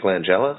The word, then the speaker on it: Langella: We wanted to talk about, the other person that was kind of in the works was Frank LaGaglia, how do you say his name? – Frank Langella? The guy who played Skeletor.